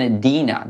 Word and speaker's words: Edina: It's in Edina, though I don't know where Edina is. – How do you spell that? – E-D-I-N-A. It sounds like your aunt. E-D-I-N-A.